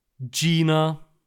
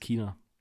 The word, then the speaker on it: China: Okay, ähm, das heißt, die offizielle Aussprache im Deutschen lautet Gina. – Mhm. – Nein, das heißt China. China. – Äh, es ist China.